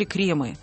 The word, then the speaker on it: кремы: На самом деле кремы. Кремы, кремы и кремы.